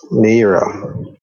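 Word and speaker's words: Nayyirah: Nira. It's N A Y Y I R A H.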